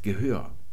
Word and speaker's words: Gehör: Wenn ihr aber dann noch ein bisschen weiter skandiert, geratet ihr an Wörter wie dieses hier, das Gehör. Das wird auf der zweiten Silbe betont.